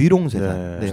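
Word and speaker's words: 玉龙雪山: 그곳에는 그 옥룡설산, 한국어 발음이 옥룡설산인데 중국어 발음이 위롱쇠산이라고 합니다. – 아, 이름도 잘 지어. – 네, 위롱쇠산.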